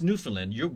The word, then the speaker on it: Newfoundland: Spelled Newfoundland, but it's Newfoundland.